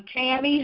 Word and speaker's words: Tamie: I'm Tammy Hollins, but it is spelled T-A-M-I-E Hollins.